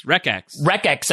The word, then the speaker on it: Wreckx: It's spelled wreckx and effect. So I don't know how to pronounce it. – Wreckx and effect. – Wreckx. – Like a T-Wreckx.